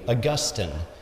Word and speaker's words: Augustine: Augustine, that is the correct way to pronounce it. Augustine is a grass, Augustine is the patriarch.